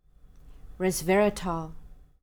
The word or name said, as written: resveratrol